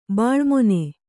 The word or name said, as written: ಬೆಳುಮೀನ